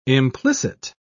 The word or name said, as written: implicit